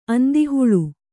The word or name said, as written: ಅಂದಿಹುಳು